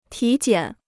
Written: 体检

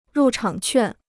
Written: 入场券

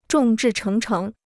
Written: 众志成城

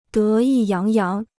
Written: 得意扬扬

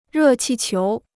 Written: 热气球

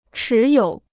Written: 持有

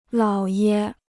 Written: 姥爷

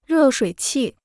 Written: 热水器

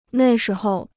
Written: 那时候

